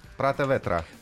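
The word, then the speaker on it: Prāta Vētra: Прата Ветра, да, именно. Правильно сказал? Э, еще раз. Прата ветра. Прата ветра. Прата ветра. Да, лучше. А вот это таким немножко с эстонским акцентом.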